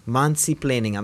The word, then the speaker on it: Mansi-plaining: Mansi-plaining, I'm saying that right. Usually it's mansplaining, but she's Mansi, and she's explaining. So it's Mansi-plaining.